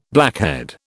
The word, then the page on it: blackhead: British English